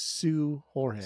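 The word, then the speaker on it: Seu Jorge: I don't think the S-E-U is pronounced Sway. I think that's actually pronounced Sue. – Okay. All right. – From my brief one Google search. So I think how you would say it is Sue Jorge.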